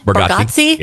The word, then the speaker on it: Bargatze: Nate Bargatze. – Bargatze.